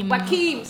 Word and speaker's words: Joaquin: Joaquin.